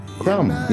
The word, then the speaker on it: Krumm: It's just Crumb, right? – Crumb, yeah.